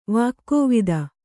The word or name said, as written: ವಾಗ್ವಾದ